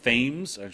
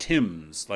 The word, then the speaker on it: Thames: I mean, they signed Eric Thames, how I believe they're pronouncing it is Thames. It might be Thames, but it's not Timms like Marcus Timms was, even though it's spelled the same.